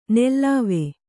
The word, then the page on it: ನೆಲ್ಲಾವೆ: nellāve